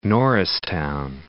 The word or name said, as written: Norristown